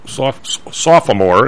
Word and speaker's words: sophomore: Is my senior year, you were sophomore, as they say on TV. There's not really three syllables in sophomore.